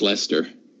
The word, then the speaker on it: Leicester: Yeah, Leicester, or however you pronounce it. – It's Lester. – Yeah, it's strange English pronunciation rules.